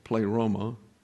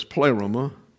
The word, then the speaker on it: pleroma: Up here is pleroma, or pleroma, the accent's on the first syllable. But we have the tendency to want to accent the middle syllable, pleroma. Well, it's pleroma.